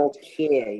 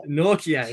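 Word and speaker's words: Nortje: Nortier, yeah. – Nortier. – TJ is pronounced like a K. – Yeah. Nortier. Yes.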